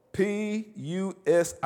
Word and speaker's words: pusillanimous: It's called PUSILLANIMOUS. I heard somebody say it. Huh? Did you say it, Heidi? Pusillanimous. Is that right? PUSILLANIMOUS. Pusillanimous. I think I broke it down phonetically. Yeah, pusillanimous.